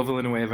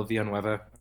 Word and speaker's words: Villanueva: Yeah, that's me, Will Villanueva, or Will Villanueva.